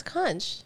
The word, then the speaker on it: conch: So I'm glad you pronounced that properly. Thank you. – You know, you want me to tell you why? Because of SpongeBob SquarePants. That's the only reason why I pronounced it correctly. – You never knew, never heard the word conch? – I thought it was a seashell until... – It's conch.